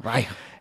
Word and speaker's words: Reich: Reich? Reich. Reich. El Marc ho pronuncia millor. Reich.